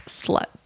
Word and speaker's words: Slxt: It's spelled S-L-X-T, but it's slut.